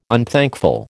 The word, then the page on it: unthankful: American English